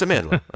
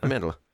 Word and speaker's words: Amandla: It's Amandla. Amandla. – Amandla.